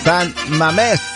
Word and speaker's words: Mamés: Hay otro santo, pero se llama, bueno, San Mamés, con acento en la E.